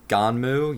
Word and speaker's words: GUNNM: This film is based on the 1990 manga by Yukito Kishiro called Gunnim, which is spelled G-U-N-N-M, pronounced Ganmu.